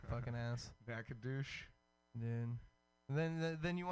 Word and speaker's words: Nacogdoches: N- It's Nacogdoches. Oh, there's no U. Almost douches.